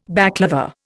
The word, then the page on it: baclava: Indian